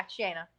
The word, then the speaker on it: Shana: Yeah, Shana.